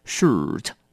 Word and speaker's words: shirt: 要小心这个字的发音了 shirt 麻烦你要记得卷舌把舌头卷到底念成 shirt 那么就对了好了那我们赶快再复习一下这些字的发音 1.